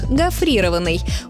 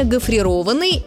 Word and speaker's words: гофрированный: При этом, скорее всего, они поставят ударение на второй слог – гофрированный. Увы, этот вариант хоть и распространенный, но неправильный. Нормативное ударение «гофрированный» – это причастие образовано от глагола «гофрировать».